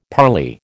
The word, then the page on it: parley: American English